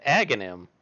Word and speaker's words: Aghanim: I pronounce it Aghanim. Like agony.